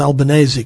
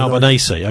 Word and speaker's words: Albanese: I think that his name is Albanese because he's of Italian origin. His family are Italian. – Albanese, okay, yeah.